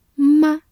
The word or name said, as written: 吗